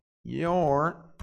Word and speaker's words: you're: Pronúncia é your. Your é a mesma pronúncia, tá? A gente vai até colocar aqui. Your é igual a pronúncia de your.